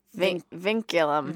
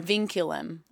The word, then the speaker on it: vinculum: It's the vinculum. – Vinculum. I said it wrong.